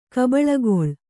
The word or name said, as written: ಕಬಳಗೊಳ್